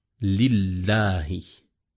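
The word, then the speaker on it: لِلَّهِ: Lorsque tu dis par exemple « Lillahi », il y a une shadda qui doit être prononcée.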